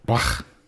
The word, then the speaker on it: Bloch: It's, I don't know if you pronounce it Bloch, but it's B-L-O-C-H. So Bloch. – I'm going to say it's Bloch.